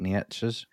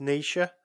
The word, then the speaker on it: Nietzsche: And then I'm terrible at pronouncing this guy's surname, but I think it's Friedrich Nietzsche's. He claimed in 1882, God is dead. God remains dead. And we have killed him. – So I think that's, I would say that's Nietzsche.